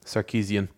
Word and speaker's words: Sarkisian: Well, I think the big thing is, like we said, on the hot seat is Steve, I cannot pronounce his second name, Sarkisian, as the offensive coordinator. – That was very good. – Appreciate that first time.